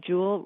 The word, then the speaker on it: Juul: Juul rhymes with cool. Mm. It sounds like something very valuable, as in a J-E-W-E-L.